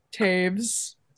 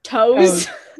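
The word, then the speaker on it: Toews: Because how do you say like Jonathan Taves? – Taves. – Toes.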